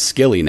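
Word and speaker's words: Scilly: Now I'm getting that part wrong again, but it's, I know it's pronounced Skilly now, cause I got that wrong last week.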